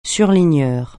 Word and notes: surligneur: French

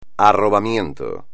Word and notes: arrobamiento: espagnol

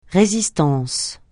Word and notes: résistance: francais

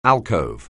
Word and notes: alcove: English

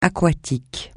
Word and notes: aquatique: francais

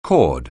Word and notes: chord: anglais

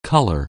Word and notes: color: anglais

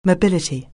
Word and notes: mobility: anglais

